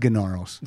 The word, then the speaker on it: Gnarls: It's pronounced Gnarls.